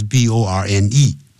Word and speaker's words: borne: 过去分词是 borne，也都念成 born。